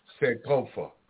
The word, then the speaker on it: Sankofa: Sankofa. – Sankofa, right.